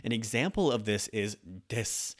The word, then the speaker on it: desu: When you say desu at the end, the predicate, if you will, at the end of a Japanese sentence is not pronounced desu, even though it's the su sound, the u at the end of this word is going to be cut off and it's just going to be s.